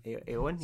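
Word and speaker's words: Éowyn: Yeah, you can also play as Frodo, and I always get this name wrong. It's Eowyn? Eowyn? – Eowyn, yeah.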